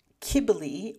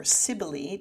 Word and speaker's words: Cybele: First of them had been a local Anatolian goddess, Cybele, or Cybele, depending on how you want to pronounce the C, who was later merged with the Greek goddess Artemis.